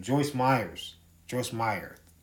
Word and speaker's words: Joyce Meyer: Joyce Meyer, that's how you pronounce her name, and how she is a false teacher.